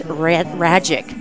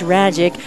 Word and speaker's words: Radjic: sweeping off that dust is the righty Max Radjic. And as I've been told, it rhymes like magic, and he's going to perform a little magic act on the hill. That's Radjic,